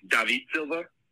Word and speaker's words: David Silva: His name is actually pronounced David Silva, and he's a fucking Man City legend.